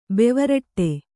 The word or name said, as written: ಬೆವರಟ್ಟೆ